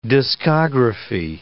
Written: discography

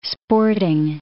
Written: sporting

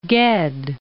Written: Guesde

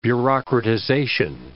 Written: bureaucratization

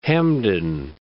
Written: Hamden

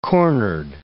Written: cornered

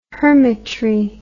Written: hermitry

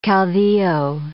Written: caudillo

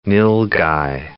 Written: nilgai

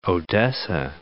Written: Odessa

